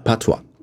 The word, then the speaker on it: Patois: Patois. – Patois. – Patois. – Patois. So, yes. Wo ist die Betonung auf das Erste, ja.